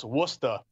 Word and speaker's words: Worcester: If you go further west, Worcester, which is pronounced Worcester, Pritch, just so you know, they're the new Red Sox AAA.